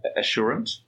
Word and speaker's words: Assurant: Have I got the pronunciation right? Assurant? – Yes, you do. That's correct.